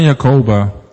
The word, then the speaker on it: Jacoba: Zwani en Jacoba, zeg ik het goed? Ja. Zwani en Jacoba.